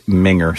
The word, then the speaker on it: minger: It is minger, silent G. – Minger. – Minger. – Oh, minger. – What? – Oh, minger. – Minger. – Oh, wait. – No, that's. I don't know. – Silent or soft G? – It says, he says silent G, which would be minner. – Yeah, that'd be minner. – Yeah, like a minner.